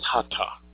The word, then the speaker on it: Hatha: It's not Hatha yoga, it's Hatha yoga. There is no th sound in Sanskrit. And if it's properly written, it would be H-A under dotted T, H-A, which would give you the reference to the correct Sanskrit letter and pronunciation. So it's Hatha yoga.